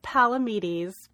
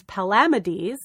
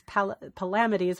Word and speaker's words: Palamedes: And I said Palamedes, but it is not Palamedes because I actually looked up pronunciation. It's Palamedes. So it's Palamedes, which I'm...